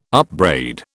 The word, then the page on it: upbraid: British English